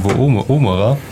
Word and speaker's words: Woomera: W-O-O-M-E-R-A.